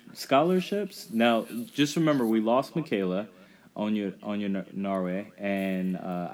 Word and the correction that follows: Onyenwere: Onyenwere, and she is.